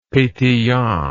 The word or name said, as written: petillant